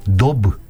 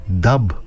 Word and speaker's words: daube: Γράφεται D-A-U-B-E, Dub, Dub.